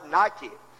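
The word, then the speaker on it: nike (νίκη): It is spelled like our word Nike, N-I-K-E, but it's pronounced nike.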